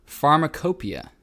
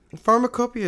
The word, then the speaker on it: pharmacopoeia: And there's more evidence in the world's, I cannot pronounce this word, the world's first pharmacopoeia. – Pharmacopoeia is exactly correct.